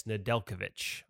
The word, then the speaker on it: Nedeljkovic: He was the goalie coach there working with Alex Nedeljkovic. I hope I said that right because I screw names up. – Well done. Nailed it.